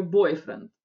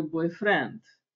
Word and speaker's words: boyfriend: Ударение всегда падает на первую половинку, да, поэтому по-русски мы как раз чаще эти слова, даже те, которые взяли из английского, мы переиначиваем с ударением в конце. Мы говорим «мой бойфренд» по-русски, «weekend», а по-английски ударение должно быть в начале.